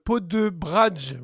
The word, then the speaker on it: Poděbrady: Alors, je le prononce mal, c'est une ville tchèque dont on peut le prononcer à la française. Alors, on va dire Poděbrady. Voilà.